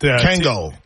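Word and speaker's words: Kangol: Kangor hats. – Kango. Yeah, he's in. – Did I say it wrong? – Kango hats. You said Kangor.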